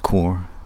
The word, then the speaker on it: Quarr: passing by the remains of Old Core Abbey, pronounced Core but spelt Q-U-A-R-R.